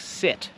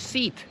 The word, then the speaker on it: sit: Na przykład litera I, po polsku I, tak jak sit. Często mówicie seat zamiast sit. I to jest niepoprawne, to jest inne słowo.